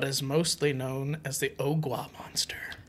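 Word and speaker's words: Ogua: And it's not spelled like Ogwa as in like water in Spanish. It's O-G-U-A. – Ogwa? – Ogwa monster.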